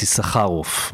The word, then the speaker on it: Issacharoff: It's Issacharoff, but because I know that you're American, I told you right at the beginning go for the easier version, which is Issacharoff.